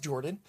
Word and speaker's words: Jorphdan: My name is Jordan, with a silent PH in the middle, and I am joined always by my wonderful co-host, Sir Lucian, over at Sir Lucian Gaming.